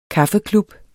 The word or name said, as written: kaffeklub